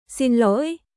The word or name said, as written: Xin lỗi